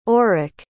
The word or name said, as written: aurik